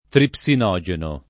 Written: tripsinogeno

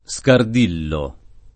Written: Scardillo

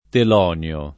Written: teloneo

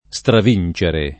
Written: stravincere

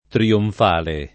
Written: trionfale

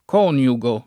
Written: coniugare

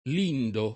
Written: lindo

